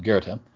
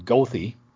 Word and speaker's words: Goethe: which is near a street which it's anybody in literature would pronounce it Goethe, but everybody in Chicago pronounces it Goethe, all the cab drivers.